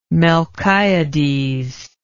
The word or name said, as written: melchiades